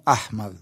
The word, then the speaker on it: Ahmad: Pablo Amad se escribe Ahmad, el apellido, ¿no? Ahmad, sería en árabe.